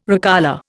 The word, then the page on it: recolour: Indian